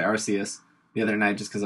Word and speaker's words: Arceus: There, it's on Bulbapedia, but the correct pronunciation, because I'm so good at pronouncing Pokemon name, is Arceus. Arceus, uh, and I think I think that's right.